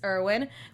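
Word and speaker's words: Erwin: Or um, Irvin, which in the English dub they pronounce it Irvin, and uh, when you read it in the subtitles, it's Irwin. So well, it's a it's German, it's German, yeah, so, so Irvin, Irvin, is correct.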